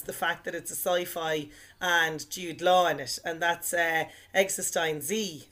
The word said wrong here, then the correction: eXistenZ: I think it's pronounced Existenz. – I thought you were going to say Existen Z. – No, just Existenz, because I saw that in the cinema.